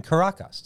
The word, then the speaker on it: Caracas: Introduce Manny, a young Venezuelan musician passionate about Afro-Venezuelan music in a poor neighborhood in Caracas. Did I say that right? – Yeah, pretty good.